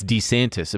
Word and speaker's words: DeSantis: After, by the way, it's DeSantis. Apparently, that's how he pronounced it himself, his own name.